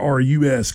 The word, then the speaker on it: Cabarrus: But Cabarrus, Cabarrus County.